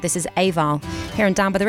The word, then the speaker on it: Eabhal: and together they are Eabhal, but that's spelled E-A-B-H-A-L, and the single is a traditional Gaelic song which they reimagined with original arrangements and compositions as well.